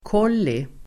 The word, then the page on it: kolli: [k'ål:i]